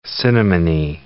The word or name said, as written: cinnamony